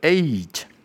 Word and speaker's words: eight: 还有就是 eight，这个字乍听之下很简单，但很多人其实会忽略掉一个细节，就是那个 eight，它的 a 要有 e 的声音在中间，eight， OK，那很多人就会念太快，就变成 at，这是错误的。正确的发音是 eight，